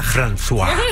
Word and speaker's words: François: François.